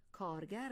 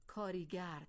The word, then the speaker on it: کارگر: بله، کارگر درست است، ولی بسیاری از مردم اکنون این کلمه را کاریگر تلفظ می کنند. شاید شما هم این کاریگر را شنیده باشید یا خود با کار برده باشید، ولی خوب است بدانید که نادرست است.